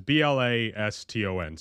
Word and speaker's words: Blaston: but I played a game called Blaston last night. I think that's how you pronounce it. It's Blaston, so it's Blaston.